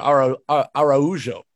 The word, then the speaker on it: Araujo: with a chance for Araujo, which I learned that's how you say it over the weekend as well.